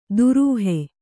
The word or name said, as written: ದುರೂಹೆ